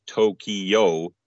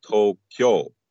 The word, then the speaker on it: Tokyo: And the word Tokyo, which we might say is Tokyo in English, three syllables, is actually pronounced Tokyo in Japanese, which sounds like two, but is actually four because of the two long vowel sounds that are counted separately. So it's counted as To-o-kyo-o.